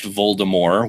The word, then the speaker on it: Voldemort: it's actually pronounced Voldemort without the T.